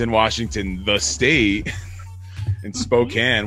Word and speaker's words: Spokane: And then Mike pronounced it Spokane and he got beat up. – I do know that one. I do know it's Spokane.